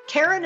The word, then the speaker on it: Karen: Karen is spelled the normal way, no goofy Y's or Q's, K-A-R-E-N, and Kataline is spelled K-A-T-A-L-I-N-E.